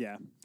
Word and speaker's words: Beniers: Matthew Beniers. I think that's how you pronounce it. It's Beniers, yeah.